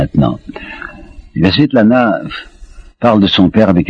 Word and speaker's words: Svetlana: Je crois que c'est une manie russe de donner des diminutifs, alors elle s'appelait, il faut prononcer paraît-il, Svetlana.